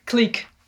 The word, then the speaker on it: Qleek: we're the founders of Qleek. – Great and, to meet you. And your company's Qleek? Qleek.